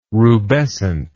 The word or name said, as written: rubescent